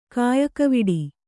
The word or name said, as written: ಕಾಯಕವಿಡಿ